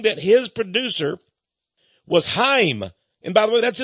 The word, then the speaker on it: Chaim: The man calls himself Chuck Lorre, but Charlie Sheen said his real name is Chaim, C-H-A-I-M, but they pronounce it Chaim in Israel and among the Jews.